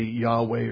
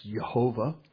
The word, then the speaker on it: Jehovah: And in that time, as he sent out, the Lord reveals his name to him, Yod-Heh-Vav-Heh, Jehovah, or some people would say Yahweh or Yahweh. I believe it's pronounced Jehovah.